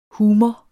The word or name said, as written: humor